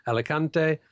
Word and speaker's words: Alicante: Alicante, Lanzarote and Reus.